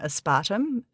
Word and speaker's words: aspartame: And fun fact, I pronounce aspartame aspartum here in Australia. I think it's more commonly aspartum.